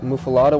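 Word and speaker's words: muffuletta: And so, I just grabbed a muffalata one day. – Muffalata. That's how we pronounce it, yeah.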